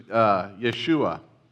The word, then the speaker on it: Yeshua: But Jesus' name in Hebrew is Yeshua.